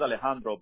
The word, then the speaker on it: Alejandro: My name is Alejandro, but a lot of people can't pronounce the J, so I just say Alex just to make it easier.